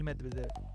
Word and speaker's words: Medvedev: Digo, o sea, quien está ahí también es este, el ruso eh, Medvedev. – Medvedev. – Medvedev. – Danil Medvedev.